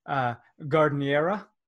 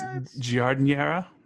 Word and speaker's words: giardiniera: I don't think you're saying that right, but maybe I don't think I am either, but it goes on like Chicago hot dogs, gardeniera. – I'm trying to look that up now. – Should I say it louder? Gardeniera. – No, maybe you are right. Maybe it's giardiniera, maybe with the soft G.